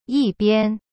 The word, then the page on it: 一边: yi-bian